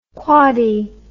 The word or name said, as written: quaddy